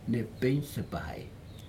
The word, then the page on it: nepig supahai: Aneityum